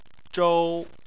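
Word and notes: zhou: tone 1

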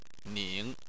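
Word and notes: ning: tone 3

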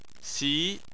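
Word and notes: xi: tone 2

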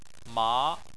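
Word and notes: ma: tone 2

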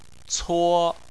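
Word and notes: cuo: tone 1

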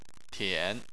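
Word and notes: tian: tone 3

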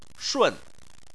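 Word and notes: shun: tone 4

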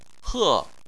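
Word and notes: he: tone 4

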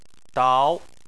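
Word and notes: dao: tone 2

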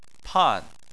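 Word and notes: pan4: tone 4

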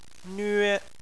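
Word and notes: nve1: tone 1

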